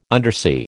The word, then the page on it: undersea: American English